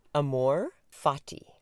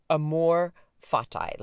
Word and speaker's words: amor fati: And it is pronounced amor fati or amor fati. Like, I think either way is appropriate.